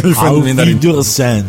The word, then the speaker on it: Auf Wiedersehen: seguito di Monty on the Run è Auf Wiedersehen Monty, adesso la pronuncio proprio con la R tedesca. – Auf Wiedersehen.